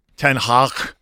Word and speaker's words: Ten Hag: Ten Hag. – That's the proper pronunciation. Very Flemish.